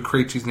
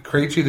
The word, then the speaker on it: Krejci: and they got David Krejci's name wrong. Instead of Krejci, they said Krejci.